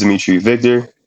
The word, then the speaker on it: Dimithry Victor: So my name is Dimithry Victor.